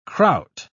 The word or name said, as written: kraut